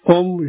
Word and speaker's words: Om: Om, you already know, as I mentioned before, A, O, Ma, three syllables become one letter, Om.